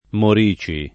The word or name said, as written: Morici